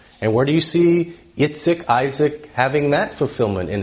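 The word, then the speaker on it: Yitzchak (יצחק): But also, Yitzchak, that's how you say Isaac in Hebrew, Yitzchak.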